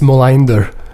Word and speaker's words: Melander: I think it's Molander. Wow. When I say that to Swedish people, their eyes light up.